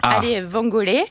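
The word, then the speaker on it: alle vongole: Je ne sais pas si je l'ai bien prononcé. – Allez vangolé. – Voilà. Allez, bon. Italienne que moi. – Mais vous avez. – L'accent tonique est sur la première syllabe.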